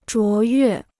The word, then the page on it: 卓越: zhuó yuè